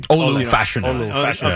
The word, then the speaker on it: Olu Fashanu: Olu Fashanu.